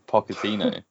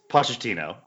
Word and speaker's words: Pochettino: With a hard K. Pochettino. – Nobody has ever said Pochettino in the history of the world. – No, it's Pochettino.